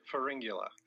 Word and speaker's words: Pharyngula: It's Pharyngula. – Pharyngula. And Pharyngula. – Is it a ring in the middle, pharyngula? – There's kind of a ring in there, yeah.